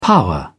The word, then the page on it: power: British English